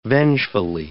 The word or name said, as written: vengefully